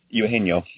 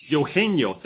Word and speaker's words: Eugenio: But Suarez, okay, how do you pronounce that first name? – Eugenio. – Eugenio, thank you very much.